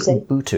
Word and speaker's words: Ubuntu: It's called Ubuntu. It's spelled U-B-U-N-T-U.